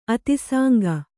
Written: ಅತಿಸಾಂಗ

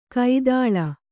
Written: ಕೈತಳ

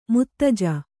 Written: ಮುತ್ತಜ್ಜ

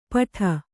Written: ಪಠ

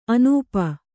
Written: ಆನೂಪ

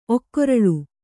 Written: ಒಕ್ಕೊರಲು

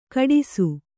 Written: ಖೋಡಾಸು

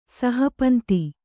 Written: ಸಹಪಂತಿ

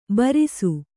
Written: ಭೃಶ

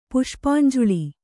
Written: ಪುಷ್ಪಾಂಜುಳಿ

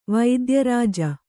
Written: ವೈದ್ಯರಾಜ

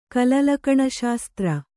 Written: ಕಲಲಕಣಶಾಸ್ತ್ರ

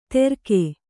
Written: ತೆರ್ಕೆ

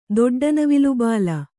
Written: ದೊಡ್ಡನವಿಲುಬಾಲ

